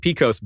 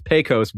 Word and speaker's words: Pecos: Brendan Towngate uh, corrects me on the pronunciation of Pecos. I said Pecos Bill. It's Pecos Bill.